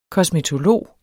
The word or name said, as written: kosmetolog